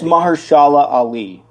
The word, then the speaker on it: Mahershala Ali: It's Mahershala Ali.